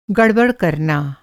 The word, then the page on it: गड़बड़: گڑبڑ